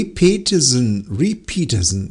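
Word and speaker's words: Ray Peterson: Repeatersen, ja, so wäre es richtig ausgesprochen.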